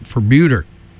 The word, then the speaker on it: Febueder: The band name is spelled F-E-B-U-E-D-E-R, and according to their Facebook page, it's pronounced like Bermuda, but with an F.